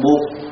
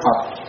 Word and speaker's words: Phật: Pháp Hòa nói Buddha, người Việt gọi là Buộc. Pháp Hòa không nói Phật, tại vì họ không phát âm chuẩn cái chữ Phật.